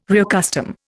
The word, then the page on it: reaccustom: Indian